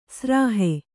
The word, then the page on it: ಸ್ರಾಹೆ: srāhe